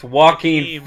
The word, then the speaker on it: Joaquin: It's Joaquin Phoenix. Joaquin Thunder. – Joaquin Thunder. – What happened to that guy? – Like, simple... Wait, are you Phoenix? – Yeah, what happened to Joaquin Phoenix? – He's a weirdo.